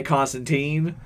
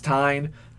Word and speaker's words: Constantine: I actually, my yearly reminder on Facebook came up a couple of days ago of multiple instances of John correcting people who say Constantine. – That is correcting Constantine. – Them as, and it's Tyne. The Tyne rhymes with fine.